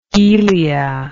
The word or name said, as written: elia